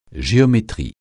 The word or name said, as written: géométrie